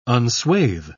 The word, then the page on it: unswathe: British English